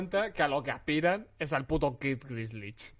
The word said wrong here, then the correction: Gilchrist: Kid Gilchrist. Gilchrist.